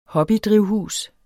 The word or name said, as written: hobbydrivhus